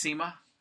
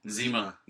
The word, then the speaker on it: Zima: It was pronounced Zima. – Zima. – Well, in Mexico, Zima. And how do you guys pronounce it? – Zima. – That's the correct pronunciation.